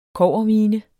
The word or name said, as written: kobbermine